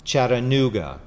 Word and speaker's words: Chattanooga: That is, not like how Yankees pronounce it with a hard T with a Chattanooga, but the T is kind of like a soft D. Chattanooga.